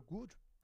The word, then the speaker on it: Good: Nicole Gut oder Gut. Die heißt halt G-Doppel-O-D. Also man das Englische für gut, das englische Wort für gut, also man würde es automatisch good aussprechen, aber sie heißt gut.